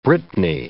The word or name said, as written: Brittany